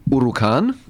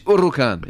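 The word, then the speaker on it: Huracán: It's a silent H. Huracan, it is. But we would say Huracan.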